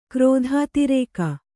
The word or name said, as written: ಕ್ರೋಧಾತಿರೇಕ